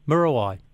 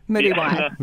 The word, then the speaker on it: Muriwai: How's it going weather-wise, season-wise, and tell me off for saying it wrong, Murawai. – Muriwai.